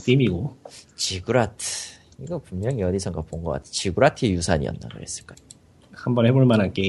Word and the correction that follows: 지구라트: 지구라트라고 하는 게 맞네.